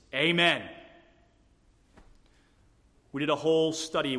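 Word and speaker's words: amen (אָמֵן): Let me pronounce the Hebrew word translated sure. It's the word amen. Amen.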